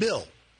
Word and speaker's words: Bill: And, of course, my longtime co-host, Dan Berger, and Phil Arbius from, am I saying that right? I still feel like I'm saying Bill. Bill.